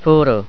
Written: furu

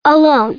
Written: alone

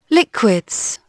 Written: liquids

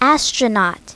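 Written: astronaut

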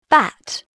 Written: bat

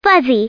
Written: fuzzy

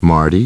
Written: Marty

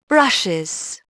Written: brushes

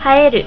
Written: haeru

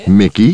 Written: Mickey

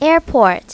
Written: airport

